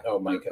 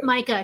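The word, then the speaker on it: Mica: It's M-I-C-A. I don't know how you pronounce it, though. – I think it's Micah. Micah, because it's short for Michaela.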